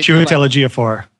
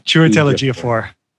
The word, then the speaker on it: Chiwetel Ejiofor: Chiwetel Ejiofor. – Yeah, see, I still can't pronounce that. Jesus Christ. I'm still voting for the operative. What was that name again? Chiwetel Ejiofor? – Chiwetel Ejiofor.